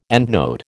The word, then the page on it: endnote: American English